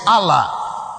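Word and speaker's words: Allah (ἄλλα): because the word there is Allah, not Allah like Muslim Allah. This one is A, I think it should pronounce Allah or whatever. A-double-L-A. That's what you find there.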